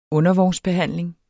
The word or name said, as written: undervognsbehandling